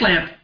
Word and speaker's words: Lampe: I'm assuming the name's pronounced Lamp, L-A-M-P-E, so maybe Lamp.